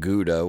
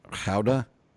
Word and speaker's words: Gouda: And for us Americans, the name of the town, we would pronounce it Gouda, which is where the cheese is from. But in in Dutch, it's Gouda.